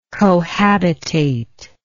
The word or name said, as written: cohabitate